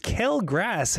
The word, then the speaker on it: quelle grâce: Quelle as in cow, and grace as in glass? With more of an R sound instead of I, she said. Why don't you just write grass then? So it's pronounced kel grass, hey?